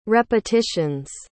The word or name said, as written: repetitions